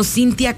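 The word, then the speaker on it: Cinthya: Estoy como Cintia Castillo Locutora. Si de repente no les aparece en mis redes sociales, es porque mi nombre se escribe con T-H-Y. Está medio complicado, pero sí, ni modo.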